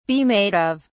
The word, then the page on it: be made of: British English